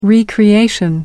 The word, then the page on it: recreation: British English